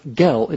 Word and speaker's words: gel: Now, it's important to remember that this is pronounced with a soft G, gel. G-E-L is not gel, it is gel.